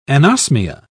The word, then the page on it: anosmia: British English